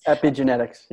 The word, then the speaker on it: epigenetics: And so what you said earlier about epigenetics and such. I don't know why I keep tripping over that word. Did I say it right this time? Epigenetics. – Epigenetics. Yeah. – Epigenetics. Thank you.